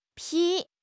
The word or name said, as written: 劈